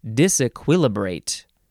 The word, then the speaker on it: disequilibrate: Disequilibrate. Nope. What is it? Disequilibrate. No. Disequilibrate. I was adding a syllable. Disequilibrate.